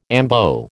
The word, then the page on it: ambo: American English